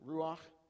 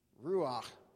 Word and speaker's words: ruach: It's ruach. You want to try that one? Ruach. Say that one with me. Ruach. Yeah, it's got that little sound at the end, you know, like you're clearing your throat.